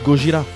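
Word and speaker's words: Gojira (ゴジラ): En fait, Godzilla, ça se prononce Gojira en japonais.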